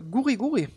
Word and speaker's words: Guri Guri: Äh, sollte ursprünglich mal Guri Guri heißen, konnten die Japaner aber nicht so gut aussprechen, denn das kommt ursprünglich aus Japan, ist aber ein Riesenrenner in Hawaii. Hat äh, ein Japaner nach äh, Hawaii exportiert und es dort an wohl äh, Arbeiter dann verkauft, dieses äh, diese kleine Erfrischung. Und weil die eben nicht äh, so gut Goody Goody aussprechen konnten, haben sie es Guri Guri genannt getauft genannt.